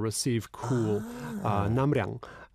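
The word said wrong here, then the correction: Namnyang (납량): Namnyang, excuse me.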